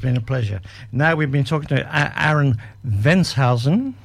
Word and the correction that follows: Venhuizen: That they have pronounced it right? – Close. Aaron Venhuizen. – Venhuizen, okay. Which is a Dutch name, but a very nice 23-year-old who...